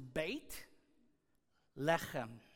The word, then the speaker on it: Beit Lechem: But Bethlehem, as we pronounce it in English, once again, is a translation of the Hebrew, and it's an Americanized pronunciation of this town. Now here we go, 4 years of intense Hebrew study in seminary. So Hebrew is read from right to left, and how it's actually pronounced is Beit Lechem. Is that right? Is that pretty good? All right, thank you, Bennett. Beit Lechem, from right to left.